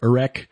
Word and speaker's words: Erech: in the Bible it's spelled E-R-E-C-H, Erech, which is essentially the name that was given to the country after the Sykes-Picot Agreement after World War I, Iraq.